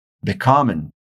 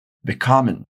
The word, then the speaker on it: bekommen: Por isso, nós falamos sempre becoming, com acento no call. Se nós tivéssemos a partícula an no começo, já seria diferente, porque ela é uma partícula separável. Então repara na diferença da pronúncia. Uncommon, becoming, uncommon, becoming. Deu para sacar?